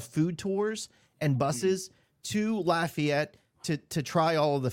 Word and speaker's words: Lafayette: Lafayette, Louisiana. – Well, you almost got it right. Lafayette.